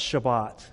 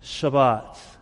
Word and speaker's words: Shabbat: And in the Hebrew, it's pronounced Shabbat. Y'all want to say that? Shabbat.